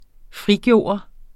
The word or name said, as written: frigøre